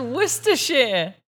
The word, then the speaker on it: Worcestershire: It's Worcestershire.